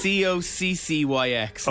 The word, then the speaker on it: coccyx: Coxix, isn't it? – It's coccyx.